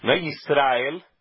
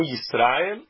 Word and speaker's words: Israel: Mas a sílaba tônica da forma correta é no Aleph. É na última sílaba. É ele, é a sílaba tônica. Então, Yisra Israel. – E não Israel, e sim Israel.